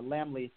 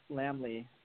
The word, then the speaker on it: Laemmle: from the Lamley Theater chain.